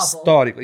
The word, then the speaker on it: Storico: It's emphasis on the first syllable. Storico, yes. Storico. The first, the first vowel.